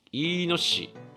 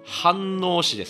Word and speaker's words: 飯能市: Z さん 70歳、埼玉県飯野市無職の方ですかね。飯野市って読むんですかね、これね。ちょっと待ってくださいね。飯能市ですね、ごめんなさい。以前も、ここからここの地域の方からいただいたと思うんですけど、もうすぐに忘れる。